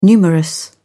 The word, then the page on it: numerous: British English